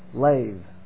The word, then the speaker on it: lev: It's lev. It's pronounced with a V, but lev.